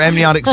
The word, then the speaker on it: amniotic: Your amniotic sac and all that. Am I saying it correctly? Amniotic? – Yeah, it's like the amniotic fluid, so amniotic sac.